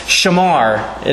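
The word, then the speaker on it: shamar (שמר): a word that is pronounced shamar in its root form. Lift your eyes. God is here. Shamar, shamar, shamar, shamar. God will keep you, guard you, protect you, watch over you, preserve you. Shamar, shamar, shamar.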